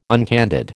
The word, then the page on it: uncandid: American English